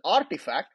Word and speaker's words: RTFKT: Finally, in Q3, Nike's recent acquisition, Artifact, it's written as RTFKT. It's an NFT firm and it's pronounced as Artifact, released the first official Nike-branded NFT, creating more inroads in the world of digital product creation.